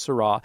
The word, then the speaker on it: Sarah: If you simply Google Cardinal Robert Seurat, S-A-R-A-H, like the name Sarah, but it's pronounced Seurat, and it's titled, Let Us Return to the Eucharist with Joy, September 3rd.